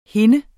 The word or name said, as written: hende